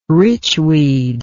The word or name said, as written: richweed